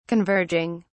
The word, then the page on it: converging: British English